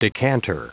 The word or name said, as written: decanter